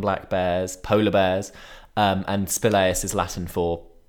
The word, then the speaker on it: spelaeus: How would you pronounce that, Emma? Spilaeus? – How do you spell it? – S-P-E-L-A-E-U-S. – I'd say spilaeus. – Spilaeus? I think spilaeus.